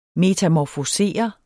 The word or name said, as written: METAMORFOSER